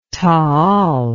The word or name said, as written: taal